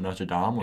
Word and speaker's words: Notre Dame: Notre Dame? – It's Notre Dame. – It is? That's retarded. Yeah. – No, it isn't. – Notre Dame's a city. Notre Dame is... – No, they're both Notre Dame.